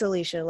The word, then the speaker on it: Alicia: It's Alicia, like Alicia Keys.